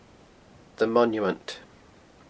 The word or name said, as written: The Monument